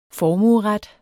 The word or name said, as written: formueret